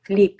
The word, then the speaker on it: Felipe: Felipe is how you pronounce it in Portuguese.